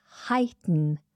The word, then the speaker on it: heighten: It is heighten. Heighten, to heighten something. We spell this H-E-I-G-H-T-E-N, heighten. Or you might hear the T pronounced heighten, heighten.